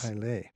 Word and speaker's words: Islay: Oh, Islay, yeah. – Islay, yeah, right. – It's pronounced Islay, but it's spelled Islay, but it's, yes. – Islay.